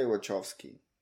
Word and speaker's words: Wachowski: How do you say it? – I would say Wachowski. – Wachowski, okay, same.